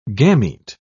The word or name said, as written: gamete